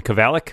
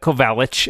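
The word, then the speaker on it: Kovalic: I will say I pronounce it Simon Kavalik, which is, Kavalik. I think... Kavalik or Kavalik would be fine, but I pronounce the hard.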